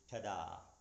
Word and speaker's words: Todah: If we just look at those symbols I just showed you, the way that's pronounced is Tada, T-O-D-A-H.